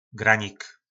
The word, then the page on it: granik: po našimu